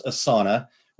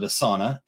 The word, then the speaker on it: Asana: I've talked about it before on some Small Business Saturdays, so we won't get too crazy here, but it's called Asana, and it's A-S-A-N-A, and it is pronounced Asana. We actually took a webinar training thing, and so one of the people that worked there called it Asana, and so we're like, okay, good, we're pronouncing it right.